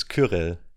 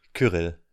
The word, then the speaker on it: Cyrill: Yeah, it's Cyril. No, fuck. It's I pronounced it English. It's Kyrill. Kyrill in Germany.